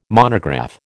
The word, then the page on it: monograph: American English